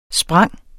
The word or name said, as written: springe